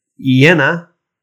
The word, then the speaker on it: Jena: On the 12th, we've got Eintracht in Jena.